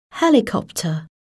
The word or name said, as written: helicopter